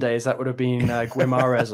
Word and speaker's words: Guimarães: Oh, wait, John might correct you on that. Go on, John. – Yeah, Guimarães. – Guimarães.